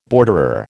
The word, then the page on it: borderer: American English